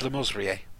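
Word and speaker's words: Le Mesurier: He always pronounced it Lemessurier. John Lemessurier.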